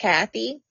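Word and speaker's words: Cathay: It's spelled Cafe, but I believe it's pronounced Kathy.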